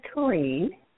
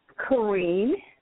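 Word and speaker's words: Karene: My name is Corrine. – Oh, sorry, what was your name? – Corrine. – How do you spell that? – It's Karen with an E on the end.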